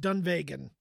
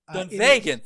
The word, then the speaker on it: Dunvegan: So just heads up, it's pronounced Dunvegan. – Dunvegan, thank you.